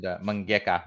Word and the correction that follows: mangaka: I always say that wrong. Mangeka. – Mangaka.